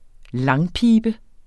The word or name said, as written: langpibe